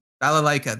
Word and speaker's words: Balalaika: Was it Balalaika? – Balalaika, that's how you do it.